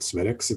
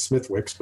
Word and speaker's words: Smithwick's: And it's like Smittix, which is what I'm drinking. It doesn't matter that Smittix, it's not Smittix, it's Smithwick's, but that's the way you pronounce it.